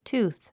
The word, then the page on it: tooth: túːθ; ツゥース